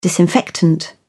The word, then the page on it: disinfectant: British English